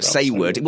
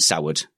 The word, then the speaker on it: Saward: You say it like Saywood, isn't it? But yes. Which is, okay, so, but it's actually Saward, isn't it? S-A-W-A-R-D. – The French say Savard, but it's S-A-W-A-R-D. You see, now you can actually find out how to spell it. – There we go.